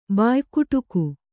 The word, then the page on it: ಬಾಯ್ಕುಟುಕು: Kannada